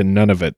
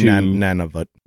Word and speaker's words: Nunavut: Nunavut. – Nunavut? – Well, you were mispronouncing it, and I was having none of it, so I had to correct you.